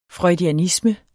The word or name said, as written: freudianisme